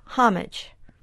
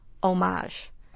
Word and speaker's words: homage: The same is true of homage, which has been in English for hundreds and hundreds of years. There's no reason to pronounce it as if it were a French word, homage.